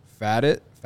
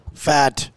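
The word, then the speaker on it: Pfaadt: And then uh, Sunday, Brandon Fadit, Fat Fad, I don't know how you say his name. It's P F A A D T. How would you say that? Fat.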